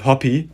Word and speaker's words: Hoppe: Mit Hünteler und Hoppe, oder wie er ausgesprochen wird, Hoppy, ist ja ein Amerikaner.